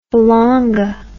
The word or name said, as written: falanga